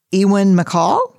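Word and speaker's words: Ewan MacColl: Is it Ewan McCall? – Yeah. – Is that how you pronounce it? – Ewan.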